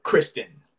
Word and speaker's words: Christon: Kristen. So C-H-R-I-S-T-O-N. – Kristen Gray. – Yeah, Kristen Gray. Yeah. So he says it's like how it's spelled, or how it's pronounced for a girl, but it's spelled C-H-R-I-S-T-O-N.